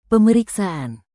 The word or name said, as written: pemeriksaan